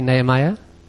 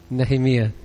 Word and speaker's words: Nehemiah: But even though your pronunciation was a bit different, we say Nehemiah, and you say, how do you say it? Nehemiah. Okay. I imagine in Hebrew it's different still.